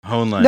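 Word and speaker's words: Hoenlein: We've got Malcolm Henlein. I don't know how to pronounce this fucking guy's name. – Honlein.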